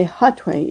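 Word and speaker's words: Hatuey: I say Hatwe, even though H is silent in Spanish. It's H-A-T as in Tom, U-E-Y, the name of the great indigenous leader from the 1500s.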